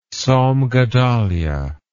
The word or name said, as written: Tzom Gedaliah